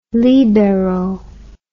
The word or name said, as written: libero